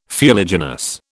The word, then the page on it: fuliginous: British English